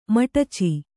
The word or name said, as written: ಮಿಡಚಿ